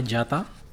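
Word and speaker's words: Gjata: Elvana Gata Flori euh, Moumajezi Mentor Aziri. Je vais, je vais demander euh, la prononciation euh, correcte. – Alors, euh, c'est Elvana Jata, et Flori Mumayesi, parce que le J c'est c'est les Y, Mumayesi, et Mentor Haziri.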